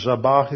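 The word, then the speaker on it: zabach (זבח): It's a soft B, so it's pronounced like a V. The ZVH, zabach, is the word for slaughter or sacrifice.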